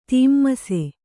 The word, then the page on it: ತೀಮ್ಮಸೆ: tīmmase